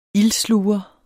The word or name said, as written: ildsluger